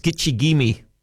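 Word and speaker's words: Gitche Gumee: It's from the Ojibwe. Pronounced Gitche-Gumee, meaning great sea.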